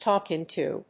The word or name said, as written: talk into